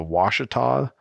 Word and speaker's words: Ouachita: Is it the Ouachita mountain range? – That's correct.